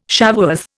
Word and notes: Shavuoth: Indian

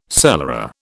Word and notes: cellarer: British English

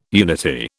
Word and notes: unity: British English

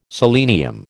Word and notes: selenium: American English

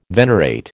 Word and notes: venerate: American English

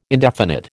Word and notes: indefinite: American English

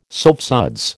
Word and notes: soapsuds: American English

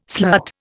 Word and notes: slut: Indian

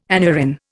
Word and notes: aneurin: Indian

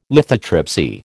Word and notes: lithotripsy: American English

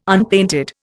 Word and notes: unpainted: Indian

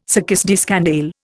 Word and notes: succès de scandale: Indian

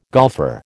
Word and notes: golfer: American English

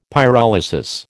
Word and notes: pyrolysis: American English

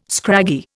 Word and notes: scraggy: Indian

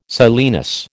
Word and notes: silenus: American English

